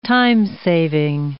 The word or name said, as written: time-saving